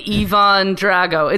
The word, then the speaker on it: Ivan Drago: You said Ivan Drogo and Ivan Drago. It's one letter.